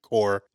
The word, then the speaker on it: Corps: Also, is it Corps or Corp? I've always wanted that. I know, because I know people say Corps, but it's always spelled C-O-R-P. – You don't pronounce the P.